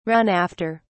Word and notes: run after: British English

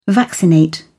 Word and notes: vaccinate: British English

